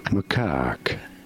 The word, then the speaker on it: macaque: You do want to stress on the second syllable, on the cack syllable. Macaque.